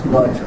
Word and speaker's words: Łódź: Just further down the line there, Miss Blanche Weintraub Wine, Charleston resident, and whose mother, Guta Blyce Weintraub of, am I saying Łódź, Poland? – Correct? Łódź. – Łódź, I'm sorry. Well, she pronounced it Łódź. – Łódź, okay. – It is Łódź.